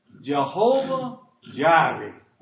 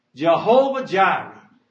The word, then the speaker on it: Jehovah Jireh: And Abraham called the name of the place, do not miss this, Jehovah Jireh. That's how you say it. Jehovah Jireh.